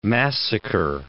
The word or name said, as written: massacre